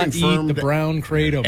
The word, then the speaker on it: kratom: Kratom, however you pronounce that. Yeah. – I think it's kratom. – Kratom, yeah.